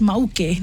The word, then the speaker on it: Mauke: And they pronounce it as Mauke, ne? So like Mauke, nice.